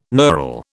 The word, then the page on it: knurl: British English